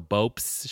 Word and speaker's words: Bopst: And it's spelled B-O-P-S-T, so Bopes Show.